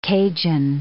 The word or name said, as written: Cajan